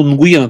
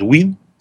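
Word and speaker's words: Nguyen: Ну, Нгуен это у нас так как бы читают, они себя называют Уин, всегда говорят Скотти Уин, там, Мэн Уин. Вот, ну, по-русски это будет Нгуен, как его фамилия пишется.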